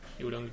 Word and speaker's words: Euron: Como Euron. Sí. Lo he dicho bien. Euron Greyjoy, sí.